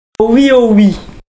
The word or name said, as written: oh oui